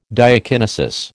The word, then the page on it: diakinesis: American English